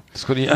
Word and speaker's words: Scott Ian: Scott, Ian, ah, Scott, ja. Ian, äh, Scott Ian, oder wie spricht man den? – Äh, Scott Ian, ja.